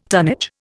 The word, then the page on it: tonnage: Indian